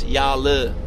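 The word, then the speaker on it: Yalı: These are the wooden houses at the waterfront of the Bosphorus, known as Yale, spelled Y-A-L-I without a dot. There's a letter which does not exist in the English alphabet. The I without a dot does not exist in your alphabet, yeah? It is pronounced U. That's why we call it Yale.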